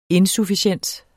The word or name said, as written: insufficiens